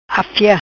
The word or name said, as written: Afia